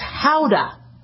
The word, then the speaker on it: Gouda: That's not how you pronounce Gouda. It's Gouda. That's apparently from the Netherlands. It's named after the city in the Netherlands, and they pronounce it with an H. So rather than saying howdy, it's Gouda for Gouda cheese.